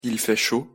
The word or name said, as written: il fait chaud